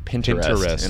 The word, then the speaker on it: Pinterest: I got it in my head that you pronounce it Pinterest. And I'm stuck on it. – Yeah, it's interest with a P. – Mm-hmm. – Interesting. – My mentor in college was one of the most subscribed to Pinterest accounts.